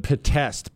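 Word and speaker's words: Pitești: You may pronounce it Podesta. But Podesta, P-I-T, it starts P-I-T, Podesta prison.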